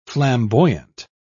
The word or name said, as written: flamboyant